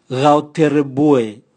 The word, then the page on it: gao terebua: Raga